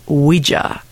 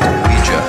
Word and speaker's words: Ouija: It's pronounced Ouija, not Ouija. It comes from the French and German words for yes. Oui, ya. Ouija.